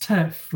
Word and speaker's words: TIRF: I guess I'm just making fun of everyone in the trans debate, because trans-inclusionary radical feminists, you shorten that, it's pronounced TIRF, which sounds like, T-I-R-F sounds like T-E-R-F, which is the more common phrase used in trans debate.